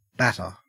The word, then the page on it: batter: Australian English